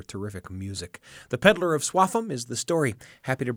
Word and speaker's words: Swaffham: And every time I read it, I want to say Swaffham, but it's apparently pronounced Swaffham.